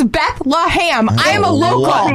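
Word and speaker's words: Bethlehem: It's Beth-LUH-HAM. – Yes! Yes! – It is not Beth-LUH-HAM. There's in no way is there. – I told you! – But it is pronounced by locals as ham.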